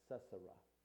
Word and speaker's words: Sisera: Deborah and Barak, or Barak, take their troops to Kadesh to battle Sisera. That's the way his name's pronounced, Sisera.